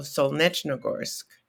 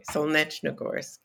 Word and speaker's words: Solnechnogorsk: No, I met her in what is today Russia, but was then the Soviet Union, in the town of Solnechnogorsk. – Thank you for pronouncing it so I didn't have to mangle it. – Yeah, okay, Solnechnogorsk.